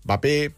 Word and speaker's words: Mbappé: Há quem diga Mbappé, Mbappé. Vamos ouvir como se diz na realidade. – Kylian Mbappé. Kylian Mbappé. You do want to pronounce the M here and insist on it.